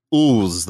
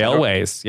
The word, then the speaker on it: Elwes: Because I was just going to call him like Ooze the whole time. – No, Carrie Elwes.